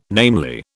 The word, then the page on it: namely: British English